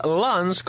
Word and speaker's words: Lens: Pero sí, están jugando el Sabah contra el Slavia Praga y ahora estamos viendo al Lens, como se pronuncia en realidad,